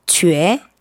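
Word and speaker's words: 쥐에 (觉): 느끼다, 깨닫다라는 뜻을 가진 한자는 중국어로 어떻게 발음할까요? 1번 쉐, 2번 쥐에, 3번 마이. – 썬! 빨랐습니다. – 2번요. – 2번. 2번 정답 맞습니다.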